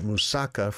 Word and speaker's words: moussaka: In Greece, there's a dish called moussaka, and that is the way you're supposed to pronounce it. I used to pronounce it moussaka for a long time, and a Greek friend corrected me. It's moussaka.